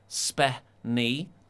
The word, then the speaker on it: spenny: So here is the Urban Dictionary definition of spenny, pronounced spe-ny, means pricey, short for expensive.